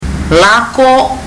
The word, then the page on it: lako: fiji